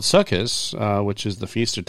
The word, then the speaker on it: Sukkot: Anyway, so Sukkot, the way we're supposed to pronounce it.